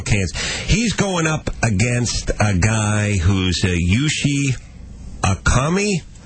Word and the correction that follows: Yushin Okami: Yushin Okami. – Yushin Okami, and his record is what? – It's 20-3.